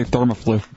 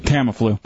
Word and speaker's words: Tamiflu: Yeah, it was Britney Spears doing the top 10 list, and then she didn't know how to say Thermaflu. – Tamiflu.